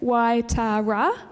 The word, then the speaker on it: Waitara: Okay, so this is up there is the town of Waitara. So that's how you say it, Waitara.